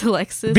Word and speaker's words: Elexis: No, just Alexis. – Because it's spelled E-L-E-X-I-S. Alexis. – Like elixir. – Yeah, exactly. – Mm-hmm. Like a magic potion, like an elixir.